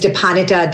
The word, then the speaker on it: Dipanwita: My name is Dipanwita Das. Yes, there is a silent W, but I also go by D.